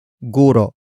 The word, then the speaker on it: guro: It is the focus marker that introduces the main topic of the sentence, and that is guro. Guro. Guro. Guro is spelled as G-U-R-O. Note the glottal stop at the end.